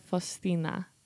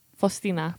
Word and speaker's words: Faustina: How do you say Faustina in Polish? – Święta Faustina. Faustina. – Faustina. – Faustina. Yeah. You said it better than I did even.